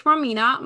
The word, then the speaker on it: Romina: So everywhere, it's Connect with Romina. My name is spelled R-O-M-I-N-A.